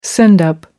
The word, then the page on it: send up: American English